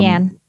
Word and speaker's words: Yan: Yan? I don't want to say her name wrong. Um, Yan. – Yan. Okay, cool.